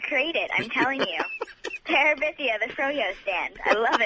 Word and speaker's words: Terabithia: Yes, and you even pronounced Terabithia right.